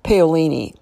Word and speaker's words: Paolini: I think his last name is pronounced Paolini. P as in Paul, A-O-L-I-N-I.